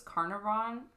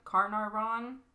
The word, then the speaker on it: Carnarvon: Lord, I don't know how to say this, and it sounds like carnivore, but it's Carnarvon. – Carnarvon. – Literally, Carnarvon.